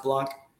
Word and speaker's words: Blank: Another one is Michael Blanc, B-A-B-L-A-N-K. It's blank, but it's pronounced Blanc.